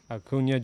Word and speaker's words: Acuña: Acuña Jr. Acuña Jr. is a beast.